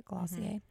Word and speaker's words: Glossier: I would say my favorite is my Glossier. Is that how you say it? Glossier.